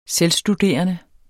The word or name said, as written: selvstuderende